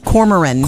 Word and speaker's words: Cormoran: Lee wants to actually thank you, for turning him on to the Corman B. Strike books. – Yes. – Or I don't think I said that right. – Cormoran. I think that's Cormoran.